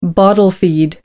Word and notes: bottle feed: American English

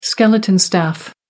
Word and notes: skeleton crew: British English

